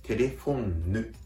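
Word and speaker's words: téléphone: ですから、発音をあえて大げさに言うと、言うとすれば、テレフォンヌというのが正しい発音ですね。テレフォンヌ、テレフォンヌ。ちょっと鼻にかけたような感じで、この N を言ってあげると、フランス語の N の発音になります。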